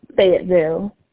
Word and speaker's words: Fayetteville: Fayetteville.